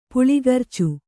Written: ಪುಲ್ಗರ್ಚು

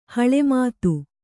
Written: ಹೊಳೆಮತ್ತಿ

